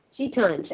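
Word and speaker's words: Gitanes: Now, I want to make sure I pronounce this biography correct. Serge Gainsbourg, A Fistful of Gitans. Is that right? – Gitans.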